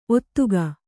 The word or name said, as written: ಒದಗೆ